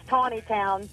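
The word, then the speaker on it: Taneytown: Tanny Town, Maryland? Is that you? Where you are? – You know, You could just ask. – Yeah. It's pronounced Tawny Town. Tawny Town.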